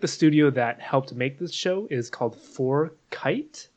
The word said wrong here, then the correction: Fortiche: For, Fortiche. – Fortiche. Okay.